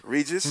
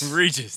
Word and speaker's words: Regis: Regis? What, how do you say his name? – Regis. – Regis. There we go.